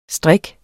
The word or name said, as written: strik